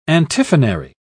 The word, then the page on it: antiphonary: British English